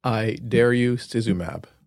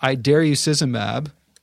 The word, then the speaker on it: idarucizumab: Idarucizumab. – Oh, I dare you. I like that. Idarucizumab.